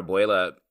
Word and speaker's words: abuela: I, for whatever reason, Abuela.